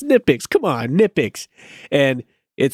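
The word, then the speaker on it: Knipex: I me me like you and pretty much almost all of uh, mechanics in here in, in the US, we say Nipix, but the actual company that makes it calls it Nipix. Like they pronounce the K in in Nipix.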